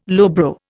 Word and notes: lowbrow: Indian

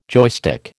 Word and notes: joystick: American English

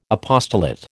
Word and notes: apostolate: American English

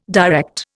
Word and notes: direct: Indian

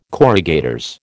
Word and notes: corrugators: American English

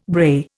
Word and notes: brae: Indian

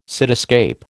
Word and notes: cityscape: American English

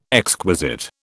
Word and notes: exquisite: British English

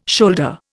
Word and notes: shoulder: Indian

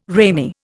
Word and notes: ramie: Indian

